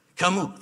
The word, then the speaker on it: Camus: Albert Camus, Camus, Camus, thank you.